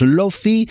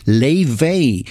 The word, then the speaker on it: Laufey: aunque su nombre se escribe Laufey, lo cual da motivos para que lo pronunciemos Lofi, la joven cantautora y productora islandesa ha indicado por sus redes que la pronunciación correcta es Leifey.